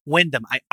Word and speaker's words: Wyndham: I am top tier on, Wyndham? Is it Wyndham? – Wyndham. – I never say that right.